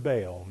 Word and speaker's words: Baal: B-A, that middle letter there is kind of unpronounced, but the vowel point underneath it gives it an A sound. B-A-A-L. I call him Baal.